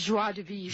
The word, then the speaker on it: joie de vivre: 30 hours a week and take off the whole month of August and joy vivre. Is that how you say it in French? How do you say that? How do you say that? – I think it's joie de vivre.